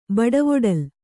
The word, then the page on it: ಬಡವೊಡಲ್: Kannada